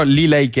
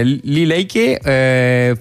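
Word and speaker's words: Lila Iké: non saprei bene la pronuncia quale sia, però Lilay K io la chiamo, perché mi sembra che c'è un accento acuto sull'ultima, quindi è Lilay K featuring Joey Badass, che sono due artisti che, per i quali io stravedo.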